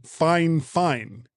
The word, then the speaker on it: Fifine: that the company's name is actually Fine Fine. Which makes no sense because it's spelled F-I-F-I-N-E, which to me is fine. Yeah, but apparently it's fine.